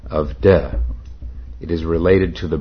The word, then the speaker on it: Te: That is spelled T-E, but I'm told it's pronounced de.